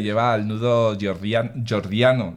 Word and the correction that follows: gordiano: ¿lo he dicho bien? Gordiano.